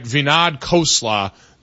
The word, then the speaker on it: Vinod Khosla: Vinod Khosla, the new owner of the Seattle Seahawks.